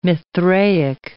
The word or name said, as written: Mithraic